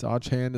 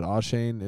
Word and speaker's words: Achane: I think he said it's Achane now. It's Achane is the right way to say it, but Achane is the way that people have been saying it.